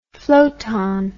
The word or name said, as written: flottant